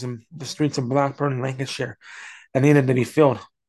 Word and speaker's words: Lancashire: I like the way he said Lancashire, which is the way you pronounce it up north.